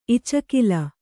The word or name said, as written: ಇಚಕಿಲ